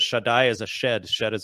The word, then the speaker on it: Shaddai: And you mentioned that sort of the biblical God is a shed. Is that how you say it? – Yeah. So one of the names for the Bible has many names for God. One of them is Shaddai, which actually etymologically is probably connected to breasts.